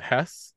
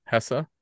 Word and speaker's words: Hesse: Is it Hess? Is that how you say it? – Hesse.